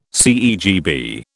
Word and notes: CEGB: British English

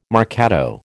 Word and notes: marcato: American English

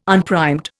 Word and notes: unprimed: Indian

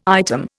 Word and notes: item: Indian

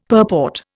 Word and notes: purport: Indian

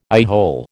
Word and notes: eyehole: American English

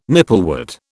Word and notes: nipplewort: British English

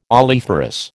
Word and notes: oleiferous: American English

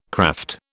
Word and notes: kraft: British English

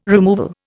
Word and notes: removal: Indian

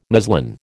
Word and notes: muslin: American English

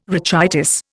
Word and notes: rachitis: Indian

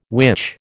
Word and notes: witch: American English

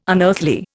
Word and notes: unearthly: Indian

